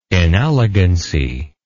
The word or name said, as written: inelegancy